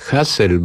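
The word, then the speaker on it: Hasil: Hassel, bo tak się jego imię wymawia, gdyż pochodził z wielodzietnej rodziny, chyba dziewięcioosobowej, braci imiona podobnie się wymawiało, na przykład Basel, Hassel i tak dalej.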